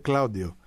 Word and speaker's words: Claudio: Βέβαια στην Ιταλία, να ξέρεις, το τονίζεται Claudio, όπως ο Κλάβδιος. Αλλά εντάξει, δεν θέλω να πάλι μπλέξω με τόνους και τα λοιπά. Είναι Claudio.